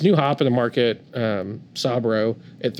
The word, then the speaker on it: Sabro: Remember, Sabro.